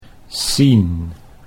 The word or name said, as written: Sien